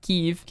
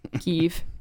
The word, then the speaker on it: Kyiv: So I was living alone in Kyiv, K-Y-I-V. – Is that how they pronounce it there? – Kyiv. – Kyiv, okay. – You have to say it like you have something in your mouth. Kiev.